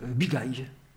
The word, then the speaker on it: Bigaye: Voilà, donc Bigay, ça s'écrit B-I-G-A-Y-E, mais à l'oreille, on entend Bigay.